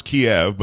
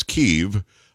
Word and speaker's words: Kyiv: We're starting to hear about the capital city that we pronounce Kiev, but it's Kyiv, I guess, in Ukrainian.